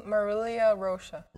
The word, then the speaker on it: Marilia Rocha: My full, Marilia Rocha.